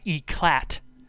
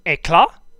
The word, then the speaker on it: éclat: The guy who voices Thomas O'Malley says it wrong, but he says, gotta strut them city streets showing off my eclat, which E-C-L-A-T is a French word. It's supposed to be pronounced éclat.